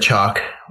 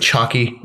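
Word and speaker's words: Chalke: And so they got one of the loves of my life, Sarah Chalk, which I only recently learned that's how you pronounce her last name, which is really depressing for my life. I thought you pronounced the E. I thought it was like Chalky.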